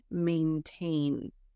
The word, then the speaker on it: maintain: So that's mine, but may, like the month of May, a, hey, may, maintain.